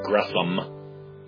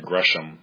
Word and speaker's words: Gresham: And the thing is, I had heard one other person pronounce it as an S instead of an SH, and I believe that was Martin Lloyd-Jones in a sermon. He mentioned Gresham Machen, and I thought, I wonder if he's mispronouncing it, because it's S-H-A-M, his middle name.